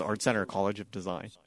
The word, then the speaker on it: Art Center College of Design: Why didn't you wind up at the Art Center College of Design, or am I saying that right? Art Center College of Design? – That's correct, yes. It is Art Center College of Design.